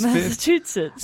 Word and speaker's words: Massachusetts: Massachusetts. – I love Massachusetts. – That's the closest correct you've ever pronounced it.